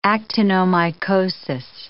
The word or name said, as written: actinomycosis